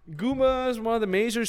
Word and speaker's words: Goomba: Goomba or Gumba? Let's say I'm Googling it. Gumba. How do you spell that? G-O-O-M-B-A? – Yes. – Ah, okay, that's called Goomba then.